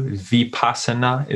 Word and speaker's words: Vipassana: Vipassana is how you say it.